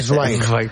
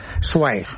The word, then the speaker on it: Zweig: Sí, pero desde que el bardo nos ha llevado por el camino bien, ahora hay que decir Zweig. – Zweig. – Es así como se pronuncia en alemán.